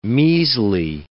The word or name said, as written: measly